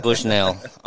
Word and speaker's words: Bushnell: Probably I'm gonna go with I ran Bushnells for a long, long time. Not the cellular ones, just the regular ones. – It's pronounced Bushnell. – Bushnells. – Bushnell. – Bushnell. A Bushnell. – Bushnell. Okay, Bushnell.